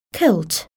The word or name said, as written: kilt